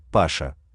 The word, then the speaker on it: Паша: Первый, Паша, Паш, А, ударение на последний слог, лидер в этой компании, именно ему отец выдает задания, которые Паша распределяет среди братьев, подтянутый, шустрый, постоянно жующий во рту спичку.